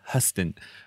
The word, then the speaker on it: Huston: I grew up in Houston, so I always thought it was Houston, and it's Angelica Huston.